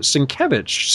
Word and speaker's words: Sienkiewicz: And then I just met him at this, you know, this just past MegaCon, and he pronounced it Sienkiewicz.